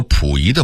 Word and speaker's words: 溥仪: 当然有些纯属俄化的字音我们还应该发正音，比如府和溥两个字，就是杜甫的甫和溥仪的溥这俩字儿。很多人把杜甫的甫念成溥，把溥仪的溥念成甫，这是必须要纠正的，咱不能姑息错字啊。